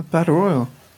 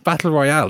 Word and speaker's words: battle royal: Some people do pronounce it battle royale. – I know, but wrongly.